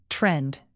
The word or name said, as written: trend